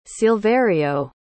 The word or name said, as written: Silverio